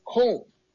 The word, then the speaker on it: Kong: It's not, I talked to people about this Confucius institution and some scholars from China, and they say that, you know, in Chinese pronunciation, Confucius is Kong, okay? And communism is Gong. So Kong and Gong is very similar.